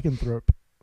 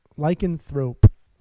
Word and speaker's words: lycanthrope: Lycanthrope. Lycanthrope. With the P.